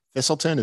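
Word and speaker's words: Thiselton: Yeah, I think Thistleton, is that how you pronounce his last name? Mm-hmm.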